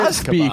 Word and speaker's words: Azkaban: Azkaban. – Oh, because one is a secret Azkaban.